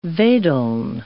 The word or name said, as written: wedeln